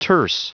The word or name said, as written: terse